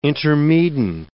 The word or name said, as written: intermedin